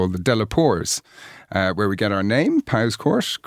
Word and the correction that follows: Powerscourt: Correct pronunciation is Poor's Court.